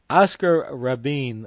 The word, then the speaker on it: Oscar Rabin: Oscar Rabin, that's how I pronounce it.